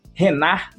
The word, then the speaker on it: Renard: E eu vou indicar também a mentoria do meu amigo Renar Machado. Se escreve R-E-N-A-R-D Machado.